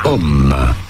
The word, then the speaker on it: homme: Yes, the H is silent in French at the beginning. Homme.